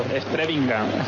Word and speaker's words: Strebinger: Strebinger, según mis conocimientos de alemán. – Strebinger, correcto. Sí.